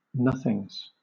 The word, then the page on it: nothings: British English